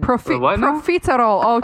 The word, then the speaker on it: profiterole: Didn't she write they were had like petaroles or peta... Some sort of petaroles they were filming. – Profiterole. Oh, God. I said it wrong.